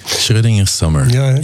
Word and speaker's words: Schrödinger Summer: Schrödinger Summer heet dat? Of spreek je dat goed uit? – Schrödinger Summer, ja.